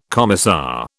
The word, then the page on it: commissar: British English